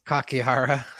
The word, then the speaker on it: Kakihara: Kakihara. – Yes. That's how you phonetically say it. Absolutely.